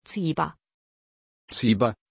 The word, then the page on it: Ziba: German